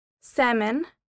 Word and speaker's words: salmon: The silent L. The L isn't pronounced if it follows the vowels A and U. Walk, half, talk, would, could, talk, calf, salmon, calm, should.